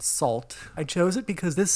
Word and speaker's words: Sault: So this song is called Fearless by the band Salt, and that's spelled S-A-U-L-T.